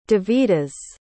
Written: devidas

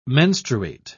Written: menstruate

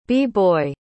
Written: b-boy

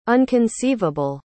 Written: unconceivable

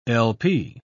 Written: lp

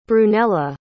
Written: brunella

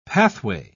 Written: pathway

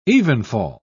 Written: evenfall

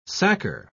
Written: sacker

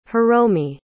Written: hiromi

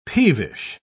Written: peevish

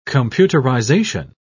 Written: computerization

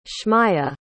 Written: Schmaiah

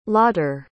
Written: lawter

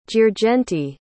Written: girgenti

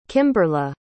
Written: kimberely

